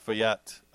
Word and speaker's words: Feuillatte: The Nicolas Feuillatte champagne.